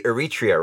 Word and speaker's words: Eritrea: It's so funny that you mentioned how, when you're in your household, you were in Eritrea. I'm saying this correctly, Eritrea, right? – Yeah, you got it, yeah.